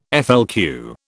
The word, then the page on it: FLQ: British English